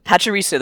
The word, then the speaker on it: Pachirisu: Pachirisu, the one where he demolishes the Salamence and...